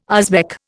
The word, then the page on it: Uzbek: Indian